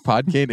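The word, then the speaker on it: Podkayne: Did you say Potty Cane? – Yeah, that's how I remember pronouncing it as a kid. – Oh, that's adorable. That's not how you say it, but... – How do you say it then? – Pod Cane. – Yeah, I was always Potty Cane. P-O-D-E-K-A-N-E, I think, right? – Potty Tell cane.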